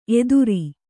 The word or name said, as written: ಎತ್ತರ